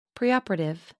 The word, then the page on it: preoperative: American English